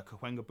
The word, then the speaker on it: Cahuenga: So I'm gonna butcher the shit out of this name uh, Cahuenga Boulevard. – Yeah. – Yeah. Did I say that wrong? Completely. – No, you said how, it right.